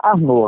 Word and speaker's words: Arnaud: A pronúncia é Arnô. Agora se escreve A-R-N-A-U-D. Fica Arnaldo. – Ah. – É com U, não é com L. – Certo. – Okay, e o D é mudo.